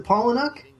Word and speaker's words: Palahniuk: They got Chuck Palahniuk, or however you pronounce his name. – Palahniuk, I think. – Is it Palahniuk? – Yes. – Okay, I'll go with that.